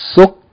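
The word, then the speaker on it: Sukkarun (سكر): The third word, please pay attention that the second letter, the kaf, has shedd. So it has to be emphasized, doubled. Okay, it should be pronounced short vowel, followed by shedd with another short vowel, and then tanween. That means it's going to be three syllables. Sukkarun.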